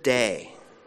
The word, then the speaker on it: dei: Our sermon title is the Greek word dei. D-E-I is how we spell it in English.